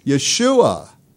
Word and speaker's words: Yeshua: How do you say Joshua if you're in Israel? Yeshua.